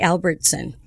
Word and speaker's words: Albertson: Did I say that right? I just want to make sure. – Cyndee Albertson. – Albertson. Yes. I'm sorry. – Yes. – I didn't want to say like the store.